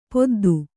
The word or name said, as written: ಪೆದ್ದಿ